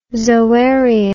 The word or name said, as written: zoarium